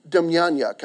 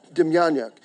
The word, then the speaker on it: Demjanjuk: I read this week about a guy by the name of John Demjanjuk. I'm probably not saying that right, but he's got J's where I think they're supposed to pronounce Y's. Okay, Demjanjuk.